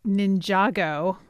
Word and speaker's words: Ninjago: Well, let's move on to Lego Ninjago. I think, Christy, that's how you say it. – Yep.